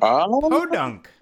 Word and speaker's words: Podunk: P-E-A-U-D-U-N-K? How do you say that? Podunk? Podunk.